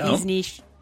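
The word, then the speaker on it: Višnjić: Timeless's Goran Visnik. Am I saying that right? – Nope. Visnish.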